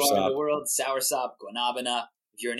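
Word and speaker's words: guanábana: I'll say it's guanabana. – Guanabana. – They say guanabana. And you know the Muppets, the Manamana song? That's what my wife says.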